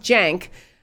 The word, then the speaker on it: Cenk: Well, there was one guy who was with them, with that group, and his name is spelled, his first name is spelled C-E-N-K, but it's pronounced Jank. Jank Uger, which is spelled U-Y-G-U-R.